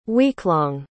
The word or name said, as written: weeklong